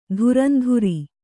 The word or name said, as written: ಧುರಂಧುರಿ